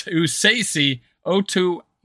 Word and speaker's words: Otomewo: Now, it's pronounced Esezi Otomewo, who actually, sorry, it's Esezi Otomewo.